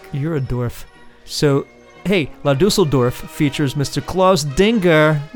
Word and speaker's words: La Düsseldorf: I can never pronounce it correctly. La Düsseldorf. Right? – Right. – The band's called La Düsseldorf.